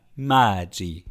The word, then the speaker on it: Maggi: Übrigens, Maggi haben wir auch immer falsch ausgesprochen. Der Mann ist Italiener. Maggi. So wirklich? – Wird Maggi ausgesprochen.